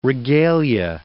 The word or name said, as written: regalia